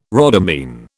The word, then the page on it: rhodamine: British English